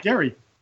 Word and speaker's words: Gerry: His name is pronounced Gerry.